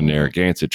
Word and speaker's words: Narragansett: And once inside, they did find 900 unarmed civilians, mostly women and children, of the Narragansett tribe.